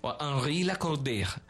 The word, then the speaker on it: Henri Lacordaire: Así dice Henri Lacordaire, así se pronunciaría en francés, pero es Henri Lacordaire.